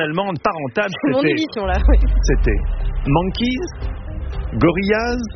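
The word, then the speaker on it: Gorillas: Gorillaz se prononce comme en anglais. Gorillaz.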